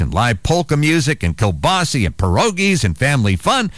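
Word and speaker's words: kielbasa: We grew up calling Kilbasi, Kilbasi. I know it's spelled Kilbasa, but we always said Kilbasi, and I know a lot of people do. But I was dictating into my phone, telling somebody about the Kilbasi Festival, and looked down, and it's spelled a Kilbasa. So maybe I am wrong.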